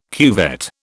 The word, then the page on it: cuvette: British English